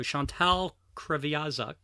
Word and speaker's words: Chantal Kreviazuk: So Chantal Kreviazuk is the correct pronunciation.